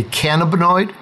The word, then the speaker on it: cannabinoid: Let's discuss, or let's clarify, what is a, you call it a cannabinoid? I didn't even say it right. – You did.